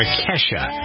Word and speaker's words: Kesha: And Jennifer's going to tell a story here that involves both Taylor Swift, to whom you are listening now, and the performer Kesha. So Very good. I'm very proud of you for saying that right.